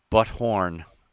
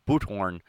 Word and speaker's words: Buthorn: His victims were Jeanette Gennett, age 58, her daughter, age 30, Candace Coons, and Coons' boyfriend, age 36, Andrew Butthorn. It might be pronounced. It might be pronounced. It might be pronounced boothorn, but it's butthorn. B-U-T-H-O-R-N.